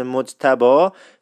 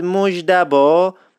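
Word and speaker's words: مجتبی: در ضمن خیلی از دوستان تو کامنت ها اشاره کردن که اسم مجتبا فقط مجدبا تلفظ نمیشد، بعضی وقتا مجدوا هم تلفظ میشد. تکرار میکنم، مجتبا، مجدوا، مجدبا و از این دست.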